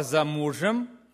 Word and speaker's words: замужем: Она зашла и стала за мужем, и положила руки на плечи мужу и ответила: вот я стою за мужем. Замужем, за мужем, ударение другое.